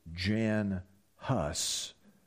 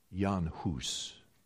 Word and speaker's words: Jan Hus: You'll see in your notes that the first name of this positive example I'd want to give to you, and you might be tempted to pronounce it Jan Hus or John Hus, but his mommy would have pronounced it a little differently. She would have named him Jan Hus.